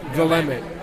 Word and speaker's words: Willamette: Willamette. – According to the people there who told me how to pronounce it.